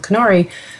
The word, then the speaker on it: Qnary: So the name of the company, we pronounce it like canary, like a bird. And our logo is a little kind of bird-shaped object, but it's actually spelled with a Q, Qnary. And it probably should be pronounced more like Qnary or Atari. But when we came up with the idea of the name, the idea came from the phrase, you know, a canary in a coal mine.